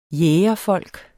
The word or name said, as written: jægerfolk